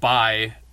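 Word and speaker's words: bai (敗): And it's pronounced like bai, approximately in most modern Chinese languages.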